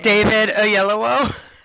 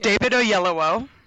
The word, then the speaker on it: David Oyelowo: David Oyelowo. – Okay, that's one of the syllables that my tongue can't form. – Okay, well, anyway, it's David Oyelowo.